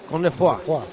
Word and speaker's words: Cosnefroy: ¿Cosnefroy o Cosnefroy? ¿Cómo se dice esto? – Cosnefroy.